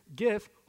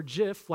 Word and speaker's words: GIF: So it's either GIF, hard G, or JIF, like peanut butter, all right? So let's see. Raise your hand if you think it is GIF, hard G, all right? Now, keep them up. Keep them up, all right? This is the hard G people. Now, everybody look around. Everybody who's raising their hand is wrong. It is JIF, like the peanut butter, all right?